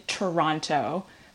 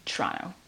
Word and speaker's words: Toronto: You say it very proper, that's what it sounds like. You say Toronto, but we kind of just like Toronto. You just like roll it off the tongue. – All right, so for the sake of professionalism, I'll say Toronto, but how I really say it is Toronto.